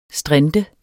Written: strint

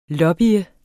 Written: lobbye

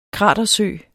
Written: kratersø

